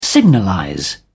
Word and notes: signalize: British English

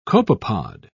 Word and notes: copepod: British English

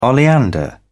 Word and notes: oleander: British English

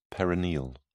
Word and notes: perineal: British English

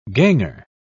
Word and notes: ganger: British English

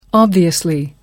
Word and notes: obviously: American English

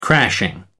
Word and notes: crashing: American English

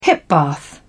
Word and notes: hip bath: British English

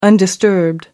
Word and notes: undisturbed: American English

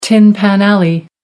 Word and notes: tin pan alley: American English

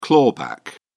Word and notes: clawback: British English

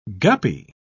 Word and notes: guppy: British English

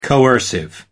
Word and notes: coercive: American English

